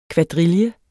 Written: kvadrille

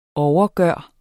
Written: overgøre

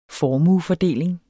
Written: formuefordeling

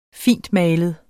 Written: fintmalet

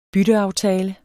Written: bytteaftale

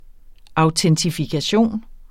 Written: autentifikation

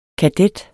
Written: kadet

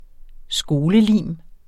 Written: skolelim

